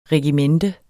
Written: regimente

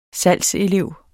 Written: salgselev

